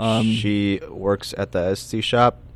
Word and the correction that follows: Etsy: Etsy. – Is that how you say it? – Etsy.